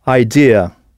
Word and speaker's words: idea: Alors à l'écrit, le mot pour idée ressemble beaucoup au français, mais la prononciation est très différente. Idea. I-D-E-A.